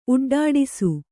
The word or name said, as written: ಉಡ್ಡಾಡಿಸು